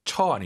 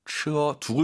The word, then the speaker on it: 车: 버스, 공交车, 네, 처 아니고 车두 글자라 생각하시고 车 발음하시기 바랍니다.